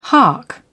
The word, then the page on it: hark: British English